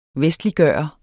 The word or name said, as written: vestliggøre